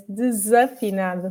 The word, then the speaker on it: Desafinado: Okay, I'm going to correct you there. It's Desafinado.